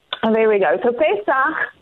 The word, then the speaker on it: Pesach: the, what you call help me with the pronunciation, uh, Pesach. Pesach. Pesach.